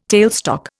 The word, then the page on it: tailstock: Indian